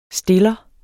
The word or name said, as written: stiller